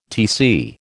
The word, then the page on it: Tc: American English